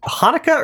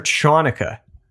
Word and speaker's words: Hanukkah: I mean, let's, by the way, do you pronounce it Hanukkah or Chanukkah? – Chanukkah? – Yeah, Chanukkah. – I think it's somewhere in between. It's like Hanukkah. Hanukkah. – That is between.